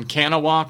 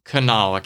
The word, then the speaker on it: Kanawha: It would have probably been good to call one person that lived in West Virginia, because whenever Clarice says that she's from Kanawha County, no one in West Virginia pronounces it that way. It's Kanawha County, and it's where the state capital is, and it made me very sad for all of you involved with the Clarice production.